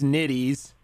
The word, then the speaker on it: Nitties: All right, talk a little bit about, a lot of people know the James Nitties, Nitty Cities, rhymes with a female body part.